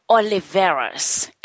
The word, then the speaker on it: Oliveras: So, how do you pronounce the last name? Is it Oli- Oli- Oliveras? – It's it's uh, Oliveras. – Oliveras. – Okay.